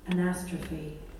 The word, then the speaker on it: anastrophe: Anastrophe.